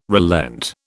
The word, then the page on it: relent: British English